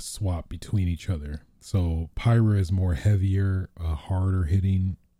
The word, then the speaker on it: Pyra: Well, Nintendo Direct, Pira. Pira. I totally butchered that. Pyra and Mythra.